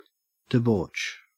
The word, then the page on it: debauch: Australian English